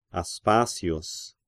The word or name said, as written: Aspasius